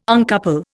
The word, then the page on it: uncouple: Indian